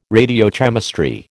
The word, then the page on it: radiochemistry: American English